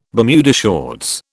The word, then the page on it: Bermuda shorts: British English